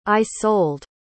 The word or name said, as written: isold